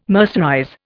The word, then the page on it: mercerize: Indian